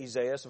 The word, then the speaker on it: Isaias: Isaias, of course, would be the Greek pronunciation of Isaiah.